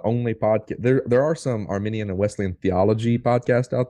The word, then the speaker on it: Arminian: And for listeners out there, Arminian, like what we are, is spelled with an I. So it's A-R-M-I-N-I-A-N, right? Mm-hmm. That's Jacob Arminius. That's Arminian theology. The other one is spelled with the E, and that is Armenian, like the country, like the people group.